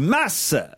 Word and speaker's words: mas: Depuis quelques jours, vous offrez un séjour au mât du Capelou et chaque fois que je vous entends, vous m'écorchez les oreilles avec la prononciation du mot mât, car il faut prononcer le S à la fin. Il s'agit du mas du Capelou.